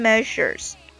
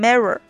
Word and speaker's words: measure: 最后还有一个这个 preparation measures，这个短语是准备措施的意思，这里有一个读音的问题，大家一定要注意这个 measure 这个辅音 z 啊，我感觉这个应该是对很多中国学生都很造成困扰的一个读音，能把这个音发好的这个中国学生不是很多哈，很多中国学生会把这个 measure 啊读成 mirror 啊，是吧，就有点像这个我们中文中的这个 r，但实际上这个辅音呢应该摩擦的更强烈一些。